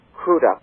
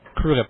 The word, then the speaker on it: Crudup: Billy Crudup was wonderful, or I believe he's called Crudup. – Crudup, yeah. – Crudup, yes, because he thanked the presenter for pronouncing his name properly.